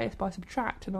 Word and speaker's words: SBTRKT: Let's listen to Wildfire by Subtract. Subtract isn't actually spelled Subtract. It's spelled S-B-T-R-K-T. And I thought they were called... – Indie. – I thought they were called S-B-T-R-K-T.